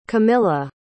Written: camila

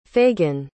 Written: Fagan

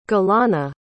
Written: gilana

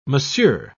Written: monsieur

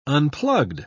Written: unplugged

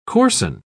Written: coarsen

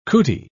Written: cootie

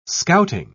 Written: scouting

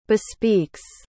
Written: bespeaks

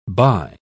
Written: buy

